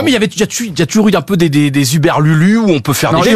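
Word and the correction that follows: hurluberlu: C'est Urlu Berlu.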